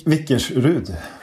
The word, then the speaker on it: Vickersrud: Och du sitter just nu i Vickersund, Tveta socken utanför mm, mm. Säffle. Gud, mm, Eller uttalar jag det fel nu? – Vick, Vickersrud.